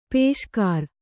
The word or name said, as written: ಪುಷ್ಕರಿ